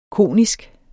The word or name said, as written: konisk